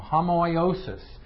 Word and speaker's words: homoiosis: It is homoiosis, that's H-O, because that's what that little backwards breather means, is an H. It's H-O-M-O-I, long O-S-I-S. So homoiosis.